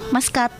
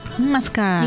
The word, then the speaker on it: Muscat: Kalau misalnya ibunya Kirana itu bacanya tuh Muscat. – Muscat.